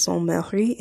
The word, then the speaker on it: Sainte Marie: Whenever something's feminine, you usually add extra E on the end, and if it has an accent on it, then, like, it's a different pronunciation. So it's Sainte Marie, and then it's Sa...